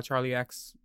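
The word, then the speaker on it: Charli XCX: Madonna, Gaga, Charlie XCX.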